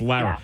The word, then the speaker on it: Lara: But yeah, clearly it wouldn't be Laura. – But the way you're pronouncing it is almost like you're saying the U. – I know, but it's different. Laura, Lar. Lore, Lar. There's, I know. So, but it's Lara.